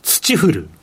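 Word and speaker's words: 霾: 土降る。一文字で表現できます。えっと、土降ると読むんですけど、土降るという漢字をネットとかで確認いただければと思います。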